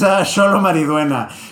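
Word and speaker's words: Xolo Maridueña: Yeah, it's Sholo Mariduena,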